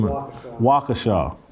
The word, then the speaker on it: Waukesha: Waukesha.